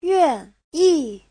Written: yuan4yi4

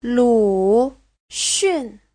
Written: lu3xun4

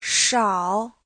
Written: shao3

